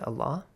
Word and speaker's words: Allah: The rule simply says, if there's a kasra before the word Allah, pronounce it with an A sound.